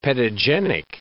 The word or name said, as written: pedogenic